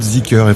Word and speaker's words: Zeekr: Mais Z2EKR, qui est une marque qui va arriver aussi, vous la prononcez Zeekr. – Alors, ça va être Zeekr, effectivement. – Ouais. Ça va être Zeekr.